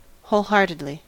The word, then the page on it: wholeheartedly: American English